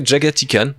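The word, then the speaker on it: Jaghatai Khan: Passons et on, on fait une autre dédicace à Alexis puisque nous parlons de Jagatai Khan, euh, qui se prononce en anglais Jagatikan d'ailleurs.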